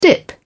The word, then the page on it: dip: British English